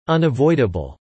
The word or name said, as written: unavoidable